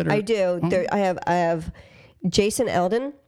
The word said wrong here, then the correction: Aldean: I might be pronouncing his last name wrong. I'm not a country guy, you know, but the famous country singer, Jason, is it Eldean? – How do you spell it? – A-L-D-E-A-N. Eldean? Aldean? – Aldean, I think.